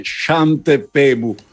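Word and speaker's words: Svante Pääbo: Lui sarebbe svedese, quindi sarebbe Svante Paabo.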